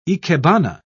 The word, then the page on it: ikebana: British English